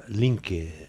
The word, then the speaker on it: LinkedIn: Póngale el acento en la última y LinkedIn.